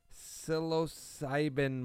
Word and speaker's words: Psilocybin: Psilocybin mushrooms.